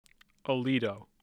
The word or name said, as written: aledo